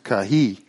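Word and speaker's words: kahi (καί): And the word there is actually kai, K-A-I, it's pronounced kahi, we would say kai.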